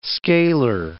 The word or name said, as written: scaler